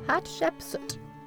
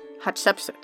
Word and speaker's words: Hatshepsut: Hatshepsut. – Hatshepsut. – There you go. – Say it with me now, everybody. Hatshepsut. Hatshepsut.